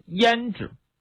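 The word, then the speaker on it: 阏氏: 匈奴首领的妻子叫胭脂，就跟我们画眉画眼线那胭脂那个读音是一样的。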